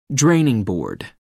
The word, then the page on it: draining board: American English